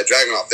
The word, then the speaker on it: Dragunov: Yeah, Dragunov, there you go, that's how you say it.